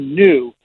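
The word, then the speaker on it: Nu: I went and looked, and it was going to be new. Not like N-E-W, but pronounced new, and I'm like, that's going to be confusing.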